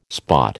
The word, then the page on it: spot: American English